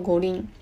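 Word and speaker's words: 五輪: 好，那它的发音叫做国林。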